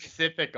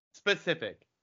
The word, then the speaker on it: specific: Pacific a lot too. – Yes. Neck. Do I? Pacific? – Specific. – Specific? That's a word.